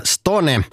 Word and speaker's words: Stone: Det finska bandet Stone, man får absolut inte uttala det fel, Stone,